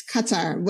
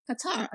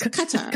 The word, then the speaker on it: Qatar: Yeah, that's the correct, because I corrected someone once, it's not Qatar, it's Qatar. And they said, no, it's Qatar, So it's good that you're correcting all of us so we get the pronunciation right.